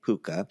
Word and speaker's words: Púca: This one, I'm going to be titling it with the P, U, with an accent mark, C, A, Púca. – P-U-C-A. Got it. – Mm-hmm. But all told, a Púca is a small shape-shifting creature whose fave thing is to just kind of pester humans.